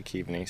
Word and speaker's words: Keaveney: Oh, you didn't, but you came close. Keaveney. Most people say Keaveney, so okay.